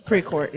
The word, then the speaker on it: Precourt: So his last name is pronounced like it is. So. – Yeah, Precourt, yeah.